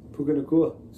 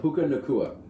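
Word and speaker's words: Nacua: We got to give a little love to our boy, Puka Nuka. – Puka Nakua. – Sorry, I always butcher that. Puka Nakua.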